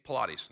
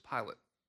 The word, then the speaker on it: Pilate: Well, if some of you, when you read Pilate, you may think Pilates. No, they're spelled the same, okay? I've suffered under Pilates. This is different. This is suffering under Pontius Pilate, okay?